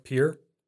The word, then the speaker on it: Pierre: Ohio, Columbus, Iowa, Des Moines, Indiana, Indianapolis, Arizona, Phoenix, South Dakota, Pierre, even though it's spelled Pierre, Texas, Austin.